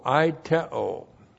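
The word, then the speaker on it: Aiteo (αἰτέω): A-I-T-E long O. Aiteo.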